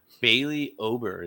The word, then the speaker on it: Bailey Ober: Let's talk about Bailey Ober, is how I say his name. – Spot on.